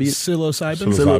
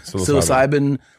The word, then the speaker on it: psilocybin: Is it psilocybin? – Psilocybin. – Psilocybin.